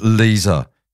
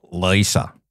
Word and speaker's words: Lisa: Someone told me once, and it's just stuck with me, that it's Lisa with a soft S and not Lisa.